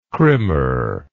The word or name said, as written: krimmer